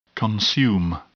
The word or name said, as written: consume